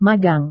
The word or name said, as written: magang